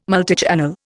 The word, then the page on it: multichannel: Indian